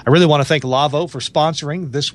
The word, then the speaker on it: Lawo: If you want to go to the Lavo website, L-A-W-O, it's a German name. It's pronounced Lavo, but it's L-A-W-O.